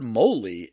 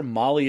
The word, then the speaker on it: moly: He says, I've always heard moly, as it has to rhyme with holy. I'm pretty sure Molly is something a tad naughtier.